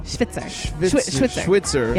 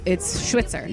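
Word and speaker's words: Schwyzer: And then 15, we had Professor Hugo Schwitzer. – Yes. I'm so bad. – It's spelled like Schweizer. – Right. – But it's Schwitzer.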